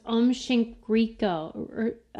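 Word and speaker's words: Aum Shinrikyo: Yeah, I always have to look because it's pronounced Om Shinriko. I always get it wrong.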